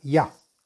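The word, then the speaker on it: Yah: Se deberá meditar el santo nombre de Yud Hei, que se, que se suena y se pronuncia Ya.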